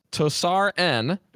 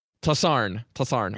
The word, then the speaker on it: TossarN: I believe I'm saying that right. Maybe just Tossar, right? Probably just saying it super weird, but welcome to the show. – Thank you. Thank you. – Go ahead. – It's pronounced TossarN.